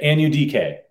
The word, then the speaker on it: Anudike: It's Felix Anudike Uzama. That's how you say it. Anudike. A-N-U-D-K.